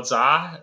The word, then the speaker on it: Zha: And you can find me on Twitter at Carl Zha. That's just my name. So Carl spelled with a C, C-A-R-L. My last name, Z-H-A, that's zebra, Z as in zebra, H as in Henry, A as in apple.